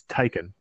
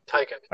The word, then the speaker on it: Taycan: I want to get I want to get his pronunciation right because it's not. It's not. It's it's Taycan. Taycan.